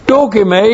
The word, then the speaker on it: dokime: Dokime is an even greater word describing virtue number two. D-O-K-I-M, long E, dokime.